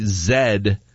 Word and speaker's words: Zed: Rusty in Amherstburg says people say Zed and other people say Z.